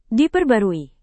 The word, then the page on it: diperbarui: indonesian